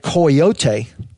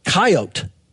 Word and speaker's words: coyote: you could hear the Mexicans say coyote, the Indians say coyote, and all the trappers would say, they couldn't pronounce that with three syllables, they would say coyote.